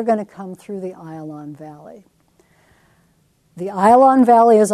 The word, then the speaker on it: Ayalon: It's pronounced Ayalon, even though most texts are going to say Ajalon.